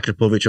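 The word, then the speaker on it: Akrapovič: And we've talked about Akrapovic all the time. We know that's how we pronounce it.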